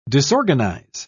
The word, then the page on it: disorganise: British English